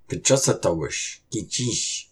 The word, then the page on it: Kucusutawush kicish!: Algonquian